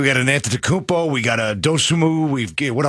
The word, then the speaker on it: Antetokounmpo: Giannis Antetokounmpo. Did I say that right? – Antetokounmpo.